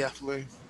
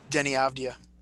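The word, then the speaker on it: Deni Avdija: I also like their draft pick a lot, Denny Avdija. Don't know if I'm saying that correctly. – Avdija. – Say it again. – Denny Avdija. – Avdija.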